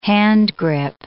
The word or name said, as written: handgrip